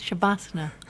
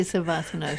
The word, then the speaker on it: Shavasana: Oh, I like the way you pronounce Shavasana. I say Savasana, Shavasana.